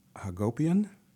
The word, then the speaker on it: Hagopian: Speaking of that, that's in the title of the book of the fellow that was on Democracy Now!, Jesse Hagopian, rhymes with utopian. I think I got closer that time.